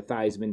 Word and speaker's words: Theismann: But Theismann changing his name also from Theismann to Theismann to rhyme with Heisman is not good.